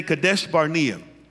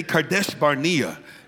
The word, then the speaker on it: Kadesh Barnea: Say Kadesh Barnea. Come on, say it like a Hebrew. Just say Kadesh Barnea.